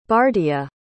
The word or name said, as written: bardia